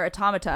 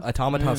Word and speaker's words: Automata: Automata sounds way cooler than Automata, in my opinion. – I hope it's Automata, because that's how automata. – It's spelled. – Automata. It's just emphasis, right?